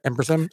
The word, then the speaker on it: ampersand: You can't put the ampersand. Is that how you say it? Ampersand? – Yeah. Ampersand.